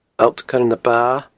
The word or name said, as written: Allt Coire na Ba